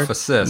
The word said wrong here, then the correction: metamorphosis: Metamor- you got to add a syllable Metamorphosis.